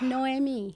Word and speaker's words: Noemi: My, most of my friends call call me by Naomi, but uh, it's Noemi.